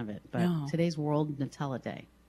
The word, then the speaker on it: Nutella: How do you pronounce that? Nutella? – That's Nutella. What I've heard everybody do it. Yes, Nutella.